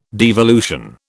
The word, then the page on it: devolution: British English